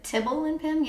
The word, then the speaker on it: Tibble and Pim: And then Timble and Pim Vintage Clothing. – Timble and Pim. – Did I say that right? – Tibble and Pim, yeah.